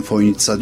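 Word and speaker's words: Fojnica: Ama çözemediğim şey, telaffuz olarak ben Foynitsa diyorum, öyle duydum. Fakat gelen misafirlerin hepsi o C'yi S olduğunu bilmiyor, TS olduğunu bilmiyor ve Foynica'ya işte gelmekten şeref duydum gibi şeyler zikrediyor.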